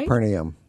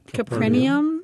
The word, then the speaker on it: Capernaum: Capernaum? Did I say that right? – Capernaum. – Capernaum.